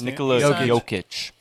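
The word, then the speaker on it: Nikola Jokić: He signed the biggest Nikola Jokic.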